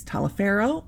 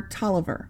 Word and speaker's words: Taliaferro: The way it's spelled is Taliaferro, and it can be pronounced one of two ways, Taliaferro or Tolliver. I think it's pronounced Tolliver, and I'll tell you why in a minute.